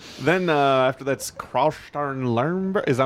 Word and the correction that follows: Christian Lembach: Am I getting this right now? No, it's Christian Lembach.